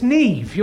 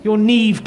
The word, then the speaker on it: Neve: It's Neve. You're Neve Campbell. Your name is Neve. – No, it's pronounced Nev. – No, it isn't. It's Neve. Everyone knows that, Neve. Nev is a man's name.